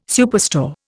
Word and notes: superstore: Indian

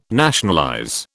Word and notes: nationalize: British English

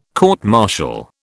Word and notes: court martial: British English

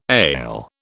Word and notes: ale: British English

